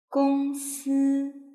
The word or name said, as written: gongsi